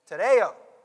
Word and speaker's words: tereo: You ready? Tereo. T-E-R-E-O.